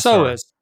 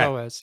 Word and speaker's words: SOAS: I don't know if it's pronounced SOAS. – SOAS. – Okay. – SOAS, yeah.